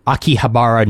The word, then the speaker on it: Akihabara: So, Akihabara News.